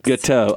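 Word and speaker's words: Gâteaux: And that's Gâteau. Spell it for everybody. – G-A-T-E-A-U-X. – Gâteau.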